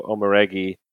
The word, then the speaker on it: Omoregie: I just love how you you you, you kind of ducked out of the end of the pronunciation, like Omoregie.